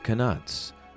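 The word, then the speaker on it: Knuts: Yes, that's right, it's pronounced canuts.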